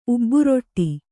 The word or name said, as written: ಉಬ್ಬುರೊಟ್ಟಿ